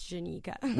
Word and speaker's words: Jenica: I wasn't going to correct you on radio, but guys, it's Jenica. – With a J. J-E-N-I-C-A.